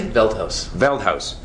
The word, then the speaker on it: Veldhuis: Veldhuis.